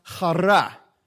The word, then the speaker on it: chara: If you go back to the Greek, that word joy from Strong's, literally is pronounced chara. Let me hear you. So you're going to learn a little Greek this morning, all right? Chara. It's kind of like when you say chala, the way that you say it, like chara, all right?